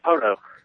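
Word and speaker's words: Poteau: Yeah, Poto. – Sort of? – Yeah, Poto. P-O like plateau, but P-O.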